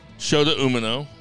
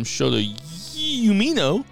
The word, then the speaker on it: Shota Umino: Okay, so we have the Blackpool Combat Club, including Shota Umino. Also, a podcast I listened to this week called him Shota Umino. And I was like, that's not how you pronounce it, but stop.